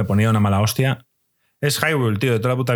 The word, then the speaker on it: Hyrule: que no se dice Hyrule, que se dice Irule. – Ni de coña, ni de coña.